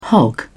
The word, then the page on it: hog: British English